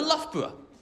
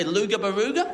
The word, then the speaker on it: Loughborough: I'm going to Loughborough. Loughborough, baby. Loughborough's a lot of fun. Loughborough is fun. Sometimes Americans mispronounce it, say Luga Baruga.